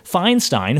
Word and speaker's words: Feinstein: I never know how to pronounce it. – It's Feinstein. It's Feinstein. – Thank you. Feinstein. I always pronounce it wrong.